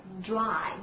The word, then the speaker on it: dry: 注意发音啊，特别注意一下这个 dr 组合大发音，它是一个在啊放气的同时呢，爆发的同时呢，它是卷舌。 OK, dry, dry.